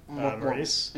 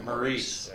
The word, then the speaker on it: Maurice: Maurice. – Yeah, Maurice.